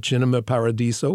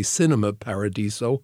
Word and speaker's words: Cinema Paradiso: The one we're starting with tomorrow night, Cinema Paradiso, or as most Americans say, Cinema Paradiso. Cinema is the Italian pronunciation.